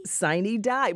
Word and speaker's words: sine die: Now, how many syllables in this, Lisa? Because I sometimes hear extra syllables like sine die. – Sine die. But, you know, we just heard from the audio there. That was a long die there.